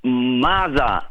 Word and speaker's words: Maaza: Yes, Maza, M-A-A-Z-A.